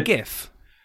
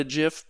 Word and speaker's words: GIF: I mean, I understand that people want to call it a GIF, but the G stands for graphics. Hard G.